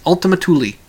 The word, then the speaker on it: Ultima Thule: Ultima Thule. That's how they pronounce it.